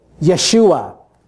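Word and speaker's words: Yeshua (ישוע): In Hebrew, the name is Yeshua.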